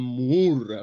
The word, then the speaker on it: Moore: Entonces, Alan Moore, que si no lo pronuncias como una vaca, no lo estás pronunciando bien, es Alan Moore, o sea, es una cosa seria, o sea, tienes que decirlo con sustancia, haría un Doctor Extraño realmente impresionante.